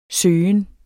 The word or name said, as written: søgen